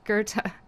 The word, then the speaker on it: Goethe: And so the guy that wrote Faust, as of this morning, I was referring to him as Goethe, but I listened to a little YouTube video. Goethe. Which is Goethe.